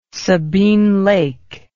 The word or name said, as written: Sabine Lake